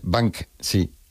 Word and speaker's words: Banksy: Pero bueno, como Bansky. – Esas palabras. Es Banksy.